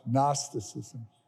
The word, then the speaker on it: Gnosticism: Gnosticism is one of those words that begins with a G, but the G is silent. Gnosticism.